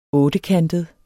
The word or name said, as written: ottekantet